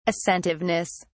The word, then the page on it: assentiveness: British English